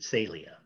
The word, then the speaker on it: Celia: My wife speaks Portuguese, and okay. She pronounces it Celia.